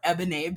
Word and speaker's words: Eboné: But yes, for those who don't know me, my name is Eboné Bell or Eboné Bell. I do have that accent on the last E, whatever you want.